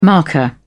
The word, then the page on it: marker: British English